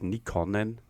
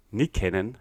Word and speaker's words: Nykänen: Und einer, der das mit Sicherheit am allerbesten beherrscht hat, das war natürlich das finnische Ausnahmetalent Matti Nykänen. Ja, ihr habt es richtig gehört. Nicht Nykänen oder Nykäkkönen. Nein, Nykänen heißt der Mann und der Kandidat, der diesen Namen besonders gut aussprechen kann.